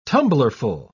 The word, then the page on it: tumblerful: British English